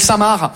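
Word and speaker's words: Saint-Marc: On est d'accord, les Français, que Samar, ça s'écrit M-A-R-C, comme le prénom Marc. Mais vous, vous mettez un saint devant le prénom Marc, le C, il est parti en grève.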